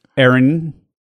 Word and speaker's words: Erin: The woman's name is Erin. The man's name is Aaron.